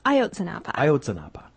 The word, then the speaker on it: Ayotzinapa: We are going to talk about Ayotzinapa. Did I say that right? – Ayotzinapa. – Okay.